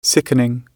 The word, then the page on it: sickening: British English